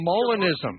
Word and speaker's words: Molinism: Oh, Molinism. Molinism. Molinism. Yeah. – Yeah. Sorry about the pronunciation. I read it. I didn't hear it.